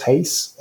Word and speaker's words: Reis: In Portuguese, it's Carlos Reis, like race, but it's spelled like R-E-I-S, Reis.